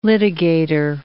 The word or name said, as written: litigator